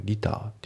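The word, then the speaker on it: liter: About a じゃなくて About a で、あとそのリターっていうね、日本語だとねリットルっていう感じで発音するんですけど、これ英語だとねリターっていう感じなんですよね。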